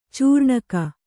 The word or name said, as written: ಚೂರ್ಣಿಕೆ